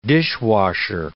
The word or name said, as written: dishwasher